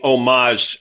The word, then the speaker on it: homage: We're paying homage, a silent H there, to Carter Braxton Worth using the 150.